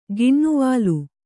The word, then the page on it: ಗಿಣ್ಣುವಾಲು: giṇṇuvālu